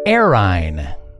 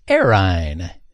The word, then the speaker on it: errhine: And the word for the second round is Arine. Arine. That's spelled E-R-R-H-I-N-E, but it is pronounced Arine.